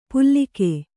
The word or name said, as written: ಪುಲ್ಲಿಕೆ